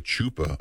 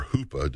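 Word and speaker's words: chuppah: The chupa or hupa, depending on how you hear it pronounced, is actually a symbol of coming up to the foot of Mount Sinai, and so many other things.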